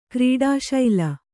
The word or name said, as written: ಕ್ರೀಡಾಶೀಲೆ